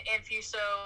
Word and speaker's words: Anfuso: I mean, Anfuso, Anfuso, you go either way. – What is the way you... – Anfuso is like when I'm feeling really fancy,